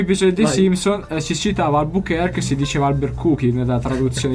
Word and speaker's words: Albuquerque: Ecco, io vorrei che tu dicessi che la pronuncia corretta è Albuquerque, non Albuquerque, come dice qualcuno in Italia. Albuquerque. Albuquerque, se andiamo a vedere,